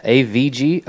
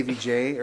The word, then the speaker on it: AVG: Yeah, AVG. Oh, I see. – I said it right this time. – Yeah, you did. – I did. I always say AVJ or something like that.